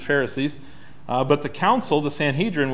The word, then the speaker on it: Sanhedrin: We may not always describe what it is, but it's the Sanhedrin, or Sanhedrin, if you like that pronunciation.